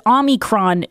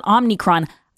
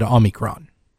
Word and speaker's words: Omicron: But I just want to say that 860 says, Grace, it's Omicron, not Omnicron. I believe I said Omicron.